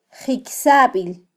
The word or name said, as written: jikysabil